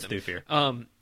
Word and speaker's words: Thufir: And there's this whole thing where Thufir, I don't know how to pronounce any of these names. – It's Thufir.